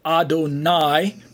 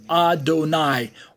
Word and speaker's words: Adonai: So on the right side of the screen, we have Adonai, pronounced as Adon plus I, and it's a title reserved exclusively for God. And with the little dots and dashes underneath, we get that pronunciation Adonai, or transliterated as A-D-O-N-A-I.